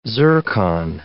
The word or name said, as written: zircon